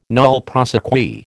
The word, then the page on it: nolle prosequi: American English